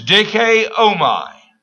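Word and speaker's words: Dikaiomai (δικαίωμα): The Greek word here is dikaiomai. I'll spell it. D-I-K-A-I-O-M-A.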